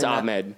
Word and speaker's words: Ahmed: Are you sure it's pronounced that way? – It's no, it's Ahmed. It's like Ahmed.